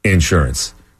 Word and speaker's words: insurance: Insurance. – Yeah, it's insurance. – Because AI... It's also the emphasis on the first syllable, not AI.